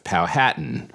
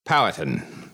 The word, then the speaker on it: Powhatan: I've gotten some emails and Twitter comments on my pronunciation of Powhatan, suggesting that the emphasis on the second syllable is both wrong and grating, that the correct pronunciation is Powhatan.